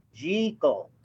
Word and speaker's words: Jekyll: So, but Jekyll and Hyde, or I should pronounce it properly, Jekyll. – Oh. – That's how they pronounce it in the movie, and I should learn to pronounce it properly, Dr. Jekyll.